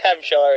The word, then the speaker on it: Hampshire: Say Hampshire again. – Am I saying it wrong? – No, it's fine.